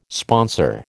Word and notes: sponsor: American English

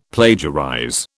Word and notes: plagiarize: British English

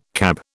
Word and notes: CAB: British English

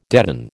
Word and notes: deaden: American English

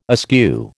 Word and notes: askew: American English

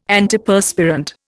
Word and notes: Antiperspirant: Indian

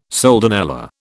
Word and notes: soldanella: British English

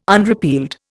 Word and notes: unrepealed: Indian

